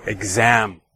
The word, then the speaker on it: exam: So the word exam, of course, has two syllables, exam. And if you can hear, the stress is placed on the second syllable. Okay. So exam, right? We don't say exam. Okay. We don't say that. We say exam.